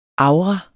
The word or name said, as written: avra